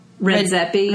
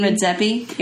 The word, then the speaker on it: Redzepi: Redzepi. – Redzepi.